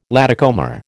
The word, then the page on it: latecomer: American English